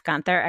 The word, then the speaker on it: Guenther: Am I saying that right? Jeff Gunther? Okay. – You got it.